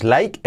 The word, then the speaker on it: like: El verbo to like, la pronunciación que nos like es la, like, like. Esa, es I es una A y una E.